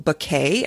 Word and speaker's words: Bequet: It's made by a company called Bouquet, and I'm going to spell it for you. It's Bequet. It's actually a company out of Montana,